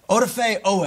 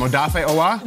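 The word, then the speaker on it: Odafe Oweh: I have mispronounced his name over 100 times on this very show. – Whose name? Odafe Owa? – No. Odafe Owe.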